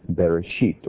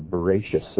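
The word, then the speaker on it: Bereshit (בראשית): Now in Hebrew, the word or the phrase in the beginning is one word, Bereshit or Bereshis as some pronounce it.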